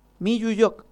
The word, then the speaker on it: Miyuyoc: ¿Cómo lo estoy pronunciando? Miyuyoc, así. – Sí, está bien. Ajá, está bien.